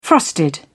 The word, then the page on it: frosted: British English